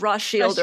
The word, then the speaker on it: Rothschild: I just don't crack open a bottle of Rothschild or Rothschild every night. So. so technically it is Rothschild, but all of us Americans, or I guess Canadians, um, actually say Rothschild.